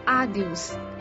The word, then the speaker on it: adeus: Goodbye is adios. – How to spell it? – A-D-E-U-S, adios.